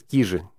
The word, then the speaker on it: Кижи: А правильно Кижи, да. И все, кто приезжает с запада, с нашей центральной части России, когда я там говорю, да, иногда все-таки говорю Кижи, они поправляют, нет, Кижи.